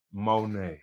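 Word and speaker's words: Monét: You gotta say her name like say Monet. – Monet.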